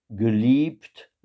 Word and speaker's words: geliebt: Die Welt, geliebt, là, faites bien attention au fait que le E ne joue aucun rôle dans. Enfin, on ne l'entend pas du tout dans la prononciation, il sert simplement à allonger le I.